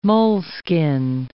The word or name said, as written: moleskin